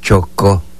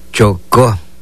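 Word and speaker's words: chokoj: Lo pronunciamos de esta manera en lengua maya. Chocó. Chocó. Chocó. Es caliente.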